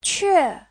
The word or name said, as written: que4